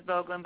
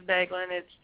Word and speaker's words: Boeglin: I know a lot of people pronounce our last name as Boeglin, but it is Boeglin. It's 100% German, and if you're not from around our areas, it's very difficult to pronounce.